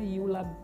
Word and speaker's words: Eula: Mas enfim, a autora é uma escritora norte-americana, Yula Bess, né? Se escreve em português seria Eula, ok?